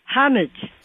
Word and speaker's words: homage: Well, I went to my dictionary like I always do to get correct pronunciations. – Oh, boy. – And it says H-O-M-J-I. So it is homage.